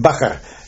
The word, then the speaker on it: Bakker: Bakker. – Pronounced Bakker. But that always used to make the English people laugh.